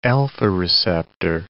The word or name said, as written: alpha-receptor